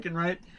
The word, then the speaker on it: Ehrenreich: But I just really don't like Alden Ehrenreich. Is that how you say his name? – Yeah, Alden Ehrenreich.